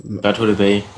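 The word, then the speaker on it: Plateau de Beille: Es war die Etappe zum Plateau de Belle hoch. – Plateau de Bay, ja. Bay, ja. Du weißt doch, dass ich mich nur blamiere bei einer Aussprache.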